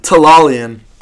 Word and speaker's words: Tellalian: Tellalian.